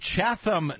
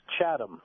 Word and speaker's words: Chatham: Chatham, New Jersey, welcome to the Mike Malloy Show, sir. – Yes. Actually, it's pronounced Chatham.